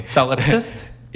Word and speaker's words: Solipsist: Uh, if I'm saying this right, sol- solopsist. – Solopsist. Solop- Mm-hmm. – Sloppy. I think that's right. it's not sloppy. Solipsist.